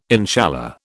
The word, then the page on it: inshallah: British English